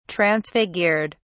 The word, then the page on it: transfigured: British English